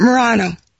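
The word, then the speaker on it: Marranos: And a lot of the marineros, is that how you pronounce it? – Murano. – Muranos, those were the ones who would be, basically they were closet Jews, and they were, yes.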